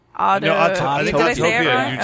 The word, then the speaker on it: Autopia: I love Or no, is it Autotopia? – Autopia. You said it right, yeah.